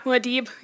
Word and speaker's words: Muad'Dib: Yeah, Muad'Dib.